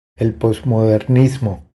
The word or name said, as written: El posmodernismo